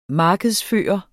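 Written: markedsføre

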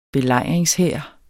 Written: belejringshær

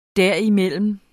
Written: derimellem

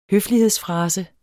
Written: høflighedsfrase

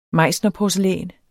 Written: meissnerporcelæn

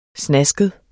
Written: snasket

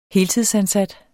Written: heltidsansat

